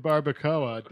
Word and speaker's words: barbacoa: It smelled like beans and cheese and barbacoa. Did I say that right? – You did.